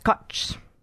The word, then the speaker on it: Kocs: In the village of Koch, spelled K-O-C-S, pronounced Koch.